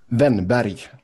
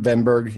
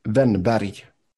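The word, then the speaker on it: Wennberg: I'm saying for the American pronunciation, Venberg, yeah, that's not too bad. – Okay, give us the proper one more time. – Ben Barry.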